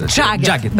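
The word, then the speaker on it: Jagged: «Джекет». Я у Димы всегда переспрашиваю, правильно ли я произношу это по-английски? Нет, здесь ты можешь... Это как Мик Джаггер. – А, то есть Джаггет.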